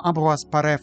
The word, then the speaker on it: Ambroise Paré: Well, in English there are numerous ways to say his name. Ambroise Paré, Ambroise Paré, Ambroise Paré, Ambroise Paré, Ambroise Paré. But let's pronounce his name Ambroise Paré for now. For your own research, you can use the spelling AmbroisePare P-A-R-E with acute accent, Ambroise Paré.